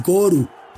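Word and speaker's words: Goru (ゴール): Es decir, gol termina en L o R, como ellos lo pronuncian, es Goru.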